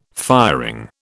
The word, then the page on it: firing: British English